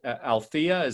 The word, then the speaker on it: Althea: He's been previously the chief technical officer at or in CTO like positions at Onyx, president and CEO at Althea. Did I pronounce that correctly? – That's correct. Althea Technologies.